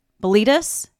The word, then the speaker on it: Boletus: I'm going to not be able to pronounce this. Bol. Boletus. Boletus? Yes.